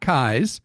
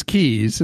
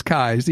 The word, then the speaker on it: Keyes: Well, you are Scott Kies, who I used to think it was Keys, and then I learned over time it's Kies, even though you spell it like Keys.